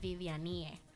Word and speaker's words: Vivianie: Bueno, pues en Facebook me pueden encontrar como Viviani Díaz Arroyo, ese es mi nombre entero, y en Instagram es Viviani Arroyo, paréntesis, el Viviani se escribe Vivian y E al final, Vivianie, para que lo puedan encontrar más fácil.